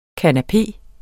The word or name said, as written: kanapé